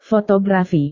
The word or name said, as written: fotografi